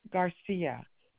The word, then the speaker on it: Garcia: Amada, AMADA, Ramos, RAMOS, Garcia, GARCIA.